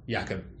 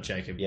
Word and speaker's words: Jakob: I've been saying his name wrong. Jakob, not Jacob.